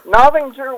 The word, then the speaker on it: Novinger: Novington, Missouri, in my grandmother's bedroom. – How do you spell that, Novington? – N-O-V-I-N-G-E-R. – Novinger. Interesting. – Novinger. – I-N-G-E-R.